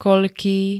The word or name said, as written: koľký